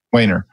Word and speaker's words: Weiner: Weiner.